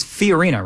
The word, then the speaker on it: Fiorina: It's Fiorina, right? – Yeah.